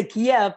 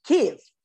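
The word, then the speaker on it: Kyiv: I used to say Kiev, but they understand now that that was the Russian pronunciation. And now people, to show solidarity with the Ukrainians, are not pronouncing it Kiev, they're pronouncing it Kiev.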